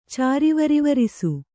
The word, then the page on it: ಚಾರಿವರಿವರಿಸು: Kannada